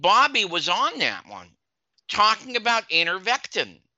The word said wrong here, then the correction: ivermectin: Ivermectin, ivermectin, yeah, I can pronounce it.